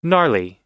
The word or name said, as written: gnarly